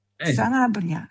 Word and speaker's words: Sanabria: Sanabria, sì, che è della Cremonese.